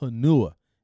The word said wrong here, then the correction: Hanau: it's Hanau. I'm like, no, that freaking thing says Hanua. They said, troop, be quiet, at ease. And I'm like, okay. It is, the name is pronounced Hanau.